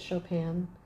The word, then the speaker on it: Chopin: And that's why I kind of think that Chopin... Is that how you pronounce it? – Yeah, it's French-ish.